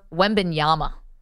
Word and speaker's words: Wembanyama: Victor Wamangama. I'm going to butcher this guy's name. He's the next Giannis. Victor Wembanyama.